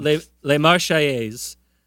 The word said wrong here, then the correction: La Marseillaise: La Marseillaise.